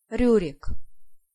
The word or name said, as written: Riurik